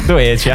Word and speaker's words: Doechii: Come Doechii si pronuncia? Hai qualche indizio su come pronunciare questo nome? – Non lo so, Doechii forse. – Doechii, allora, all'italiana. – Lei diceva Doechii. – L'ho sentito, diceva Doechii lei. Però... – Doechii. Sì, Doechii. Vabbè, avrebbe senso. Doechii. Tipo cerbiatto.